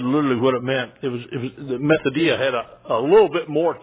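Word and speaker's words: methodia (μεθοδεία): If you're looking out, spell it M-E-T-H, like meth, some of you take. Methodia. M-E-T-H-O-D-E-I-A.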